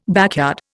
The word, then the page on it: backyard: Indian